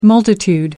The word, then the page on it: multitude: American English